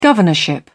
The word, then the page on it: governorship: British English